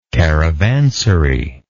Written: caravanserial

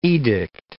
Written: edict

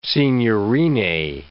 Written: signorine

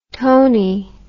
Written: toney

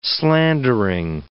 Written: slandering

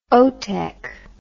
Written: otec

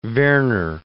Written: Werner

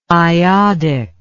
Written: iodic

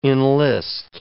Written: enlist

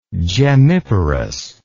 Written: gemmiparously

